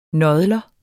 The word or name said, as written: nådler